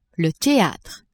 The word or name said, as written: theatre